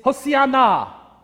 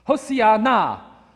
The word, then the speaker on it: Hosanna: In Hebrew, it's Hosanna. And you need to put the stress on the last na. Hosanna.